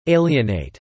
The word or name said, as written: alienate